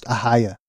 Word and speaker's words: Ohio: I learned that Ohio is pronounced Ahia this week.